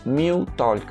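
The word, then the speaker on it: Meow Talk: Pero quien quiera estar probándola, se llama Mew Talk, que se escribe M-E-O-W, Talk como hablar en inglés.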